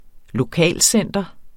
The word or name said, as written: lokalcenter